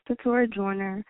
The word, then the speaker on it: Keturah Joyner: So my name is Keturah Joyner.